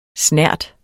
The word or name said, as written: snert